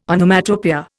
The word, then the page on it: Onomatopoeia: Indian